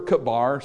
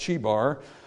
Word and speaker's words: Chebar: Now in Ezekiel chapter 1, in verse 1, we're told that Ezekiel is there with the captives by the river Kabar, sometimes pronounced Chebar, but they're there by this river.